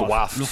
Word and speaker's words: Luftwaffe: I can never pronounce that word. – Luftwaffe.